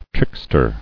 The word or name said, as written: Trickster